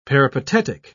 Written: peripatetic

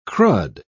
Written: crud

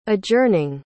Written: adjourning

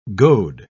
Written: goad

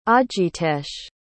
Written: ajitesh

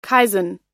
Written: kaizen

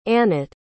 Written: Anate

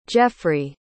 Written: jeffrey

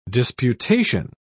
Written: disputation

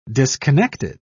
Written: disconnected

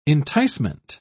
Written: enticement

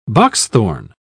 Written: boxthorn

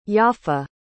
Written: yaffa

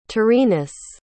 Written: taurinus

